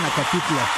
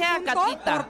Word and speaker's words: Acatita: No pueden estar bien cuando todo el tiempo le dijeron Acatitla. – Vamos a bajar. – Yo dije Acatita. – Por pronunciarlo mal, okay.